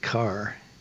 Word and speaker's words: Kerr: The practice field up until that time was what they called Carr Field, K-E-R-R, named for the superintendent. – What did you call it? – I believe most of the time we pronounced it Carr, but it looks like Kerr. Carr Field. But then later I knew B.B. Carr, and he called it Carr.